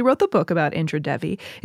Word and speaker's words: Devi: Some people who are listening to this might be like, you wrote this book, why don't you know how to pronounce Devi? Devi is how you pronounce it in Sanskrit, but she wasn't Indian and was widely known as Indra Devi.